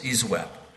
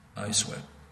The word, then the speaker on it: ISWAP: ISWAP. It rhymes with sideslap, ISWAP.